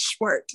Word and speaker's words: Schwert: Okay, it's Schwert. It's German.